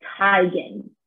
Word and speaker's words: Teigen: She said in quotes, "I'm tired of living this. It's Teigen." In September of 2018, she's correcting those who have pronounced it Teigen all these years.